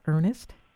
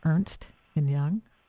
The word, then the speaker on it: Ernst & Young: By the way, Bay Area, Silicon Valley, and those that are listening around the globe, it is not Ernest, it is Ernst & Young.